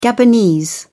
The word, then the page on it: gabonese: British English